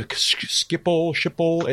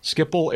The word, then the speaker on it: Schiphol: So Schiphol, S-C-H-I-P. – Schiphol Airport is in Amsterdam in the Netherlands, according to the internet.